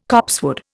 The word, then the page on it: copsewood: Indian